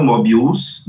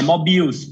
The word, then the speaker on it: Mobills: Então, a minha pronúncia era incorreta, eu botava a sílaba tônica no final, Mobills, não é? É Mobills, não é isso? – É Mobills, porque Mobills, Mobills, curiosamente, foi uma...